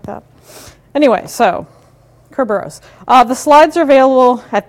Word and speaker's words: Kerberos: Greek, Kerberos, emphasis on the first syllable.